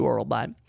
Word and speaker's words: Orolbai: M-Y-K-T-Y-B-E-K. So Mike Tybek. Orolbai. O-R-O-L-B-A-I. Orolbai. Okay.